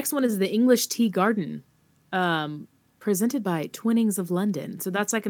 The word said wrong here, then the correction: Twinings: It's Twinings, right? Not Twinnings.